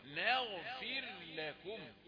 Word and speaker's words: نغفر: ما نقولش نغفر لكم لا نون رقيقة لانها مش مفخمة، الغين هي اللي مفخمة، فقول وبعدين انطق الغين المفخمة، قول ثم اتي بالمرققات التانية الفاء والراء، نغفر لكم.